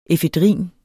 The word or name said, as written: efedrin